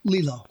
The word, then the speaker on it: Lillo: It's Lillo, Lillo, _Brancato, Brancato. – Okay. Is that the actual pronunciation, Lillo? – Lillo.